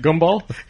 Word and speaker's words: gumball: Yeah, he said it right. How much? You went home and practiced, huh? What you were saying before? Goomball? – Goomball. I don't know. – Goomball. – How do you say it? Sorry, gumball is not a word I use in everyday speaking. – But you say gum. You gotta say gum.